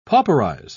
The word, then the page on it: pauperize: British English